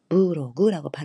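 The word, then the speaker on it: ブログ: 코진노 도스가 다치가 유튜브야 무로그, 블로그는 무로그, 무로그라고 발음하시면 됩니다.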